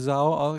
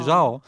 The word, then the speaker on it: Zhao: Na pewno jedna rzecz, która nam nie zadziałała, to wymawianie nazwiska reżyserki, bo widzę, że z premedytacją mówimy Zao, a to jest chyba nazwisko, które powinno wymawiać się Zhao.